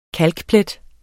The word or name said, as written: kalkplet